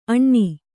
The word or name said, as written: ಅಣ್ಣಿ